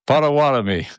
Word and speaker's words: Pottawatomie: I am known for mispronouncing names all over the country, and that's one of them. Pottawatomie.